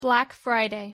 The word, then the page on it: black friday: English